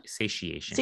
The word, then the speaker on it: satiation: Is it satiation or satiation? – Satiation. – Satiation. Okay. I said it right the first time.